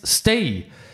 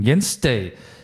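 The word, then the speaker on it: Stage: Mitte Nummer 6, Jens Stay. Das ist der Starke geschrieben wird, aber wird Stay ausgesprochen. Jens Stay.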